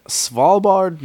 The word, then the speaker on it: Svalbard: Um in Svalbard, Norway.